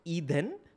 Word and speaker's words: Eden: And Eden is pronounced Eden.